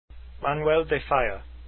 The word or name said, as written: falla